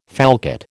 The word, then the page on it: falcate: American English